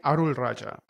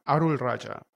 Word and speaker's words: Arulrajah: Did I say that correctly? – Naren Arulrajah. Arulrajah. You're close.